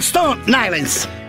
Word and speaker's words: Stone Island: Questi jeans della Stone Ainsel. – Di jeans, che c'è la... – Questi jeans della Stone Islands. – Stone Islands. – Ma perché scrivono come si legge? – Si chiama così, Stone Island.